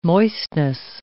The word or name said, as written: moistness